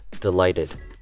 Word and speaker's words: delighted: Delighted uses a short E sound, the word lie, and with American English, when a T appears in the middle of a word, the T sounds take on a D sound instead. So it becomes delighted. Delighted.